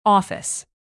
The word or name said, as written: office